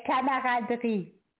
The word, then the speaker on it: camaraderie: Yes, it is French. – Pardon me? – Pronounce it in French. – Okay, camaraderie.